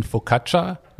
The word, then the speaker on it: Focaccia: ein Focaccia zum Beispiel, finde ich super lecker dazu.